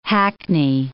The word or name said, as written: hackney